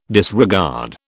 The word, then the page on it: disregard: British English